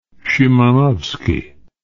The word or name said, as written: szymanowski